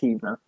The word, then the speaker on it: Heavener: Yes, and that's located in Heavener, Oklahoma, and it looks like it's spelled Heavener, but it's Heavener.